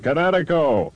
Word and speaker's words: Kinetico: Everybody knows that Kinetico is spelled K-I-N-E-T-I-C-O. – I thought the instructor said Connecticut. That means coast. – Son, you should have remembered kinetic as in kinetic energy with an O on the end. Kinetico. – Kinetico. Spell it K-I-N-E-T-I-C-O.